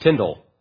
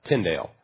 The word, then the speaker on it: Tyndale: Turns out you pronounce it Tyndale, like spindle, not Tyndale.